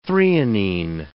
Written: threonine

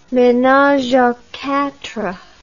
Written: menage a quatre

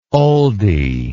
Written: oldy

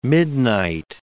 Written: midnight

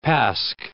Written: Pasch

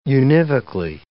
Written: univocally

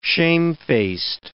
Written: shamefaced

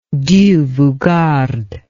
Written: Dieu vous garde

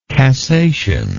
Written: cassational

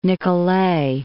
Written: Nicolet

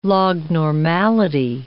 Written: lognormality

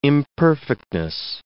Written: imperfectness

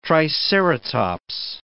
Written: triceratops

